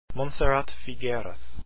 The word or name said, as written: figueras